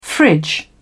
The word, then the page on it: fridge: British English